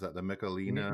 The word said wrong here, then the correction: Michelada: Michelada. – Whatever.